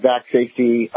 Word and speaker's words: VacSafety: Is it Vac Safety, or would you say Vax Safety? How would you say it? – I say Vac Safety, but the better one is the Substack.